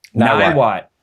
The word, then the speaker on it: Niwot: I think that's how you say the name of the town, right? Niwot? – Niwot. – Oh man, I've been saying it wrong all day. In Niwot, I pronounce it like we would pronounce it in the Midwest, and that is not appropriate for Colorado.